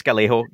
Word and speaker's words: Gallego: Yeah, so this is the side of Ruben Gallagher. Did I say that right? Gallego? I might have messed that up. I apologize if I messed up the name. But I think it's Gallego.